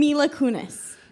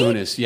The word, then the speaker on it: Kunis: Mila Kunis. – Mila right? Kunis, yeah.